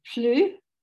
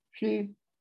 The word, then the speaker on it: plus: It is attested in Koha as the mathematical concept and also in quotations from French, where the word plus, no S pronounced there, plus means more.